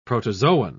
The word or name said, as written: protozoan